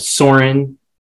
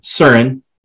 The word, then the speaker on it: Søren: And then Soren, or Søren, if you pronounce it properly, as the Danes would. But we're in the United States, so we say Soren.